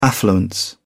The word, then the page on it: affluence: English; British English